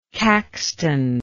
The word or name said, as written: Caxtonian